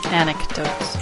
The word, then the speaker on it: anecdotes: Rather than your personal antidotes. – Anecdotes. – Anecdotes. Did I say it wrong? – You said antidotes.